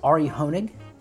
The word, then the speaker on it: Hoenig: This is Ari Honig. Am I saying that right? Is that, am I saying that right? Yeah. – Yeah.